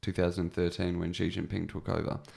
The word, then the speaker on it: Xi Jinping: Was before, because from what I understand, Xi Jinping is Xi Jinping, yeah.